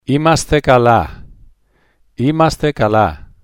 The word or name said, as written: eimaste kala